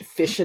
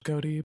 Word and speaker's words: ghoti: I just love Yu-Gi-Oh card names, like, just Fish of the Deep Beyond. – Well, they spell it G-H-O-T-I, like the joke, because, like, phonetically you can pronounce that fish even though it's spelled Goaty, but it's...